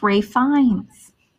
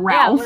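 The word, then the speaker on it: Ralph Fiennes: His name is pronounced Rafe Fiennes. – Rafe Fiennes, yeah. – You have to break it, Jake. That's right. His name is Rafe. Not Ralph.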